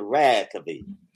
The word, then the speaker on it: Reykjavik: What did you think of Reykjavik? Or am I saying it correctly? – I believe you're saying it correct.